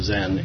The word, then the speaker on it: Zen: Chan is the Chinese pronunciation of the word that's pronounced Zen in Japan.